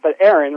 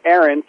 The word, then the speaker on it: Aaron: Not A.M., right, but Aaron, right? Even I say it incorrectly because, the way most people pronounce my name, Aaron, sounds identical to the girl version, E-R-I-N, Aaron. But the way you say the word is actually how the name is pronounced, at least in English.